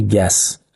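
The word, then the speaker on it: gas: خب یعنی تا گز ها، یعنی گاز، متاد تلفظ انگلیسیش تو این میشه گس،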